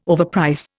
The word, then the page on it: overprice: Indian